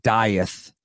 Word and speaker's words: Dieth: Was meaning to have you on like way, way sooner once I got the advance of the Dieth. If, yeah. – Am I saying that right? – Yeah, you're saying it right.